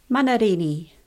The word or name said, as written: Manarini